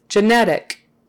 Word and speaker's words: genetic: Genetic. Accent on the middle. Genetic.